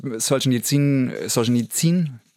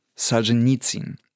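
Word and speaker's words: Solzhenitsyn (Солженицын): Naja, jedenfalls, Langrede, kurzer Sinn, Solzhenitsyn, also die russische Aussprache ist ja Solzhenitsyn. So, und im Deutschen sagt man mal Solzhenitsyn.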